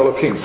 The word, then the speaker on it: Elohim: One is pronounced Elohim.